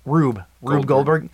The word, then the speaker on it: Rube Goldberg: Rube Goldberg. Yeah. – It's kind of like a Rube Goldberg puzzle because everything kind of runs into like the next thing.